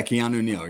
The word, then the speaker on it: Keanu Neal: Help me out with the pronunciation, because I was calling him Keanu Neal. – Yeah, Keanu Neal. Yep, exactly.